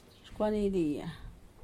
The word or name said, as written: xcuanidia